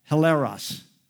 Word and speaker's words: hilaros: In the Greek language, with the right ending and everything on it, it would be pronounced hilaros. Hilaros, where we get our word hilarious from.